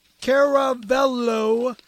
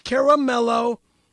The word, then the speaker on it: Caravello: Caravello is spelled with a C and never a K, pronounced Caravello, not Caramello, is easily one of the greatest comics and actors that the world has ever seen.